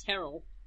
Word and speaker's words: Terrell: It's spelled Terrell, but it's pronounced Terrell.